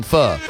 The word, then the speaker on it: pho: It's pronounced pho, son. Pho. P-H-O. Pronounce pho.